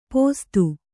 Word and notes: ಪೊಸತು: posatu

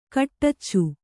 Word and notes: ಕಟ್ಟಚ್ಚು: kaṭṭaccu

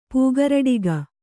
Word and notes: ಪೂಗರಡಿಗ: kannada

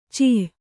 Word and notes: ಛ: cha; Kannada

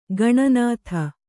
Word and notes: ಖಿನ್ನತೆ: khinnate

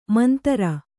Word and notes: ಮಂತರ: mantara

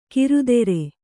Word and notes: ಕಿರುತೆರೆ: kirutere

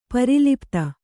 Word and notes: ಪ್ರಲಪಿತ: pralapita

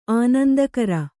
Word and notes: ಆನಂದಾಗರ: Kannada

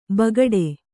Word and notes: ಬೇಗಡೆ: bēgaḍe

